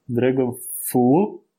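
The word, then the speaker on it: DragonflEye: начали работу над проектом Dragonfly. Причем Dragonfly и I отдельным словом как глаз, а не как fly, как муха.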